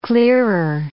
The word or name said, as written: clearer